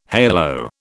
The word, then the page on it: halo: British English